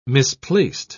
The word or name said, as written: misplaced